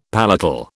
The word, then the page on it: palatal: British English